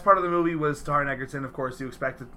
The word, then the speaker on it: Taron Egerton: Last night, holy crap, I got to see the new Robin Hood movie with Taron Egerton and, did I say that right? – Taron Egerton.